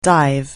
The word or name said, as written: dive